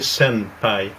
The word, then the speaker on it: senpai: Mais en réalité, on devrait le prononcer senpai, comme sensei dans le fond.